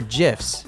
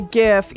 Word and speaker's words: GIF: just got a gift sent to me.